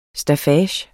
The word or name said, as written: staffage